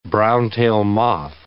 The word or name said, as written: brown-tail moth